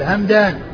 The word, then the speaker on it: همدان: فما كان نسبة إلى قبيلة فهو بالميم والدال, الميم الساكنة والدال همدان.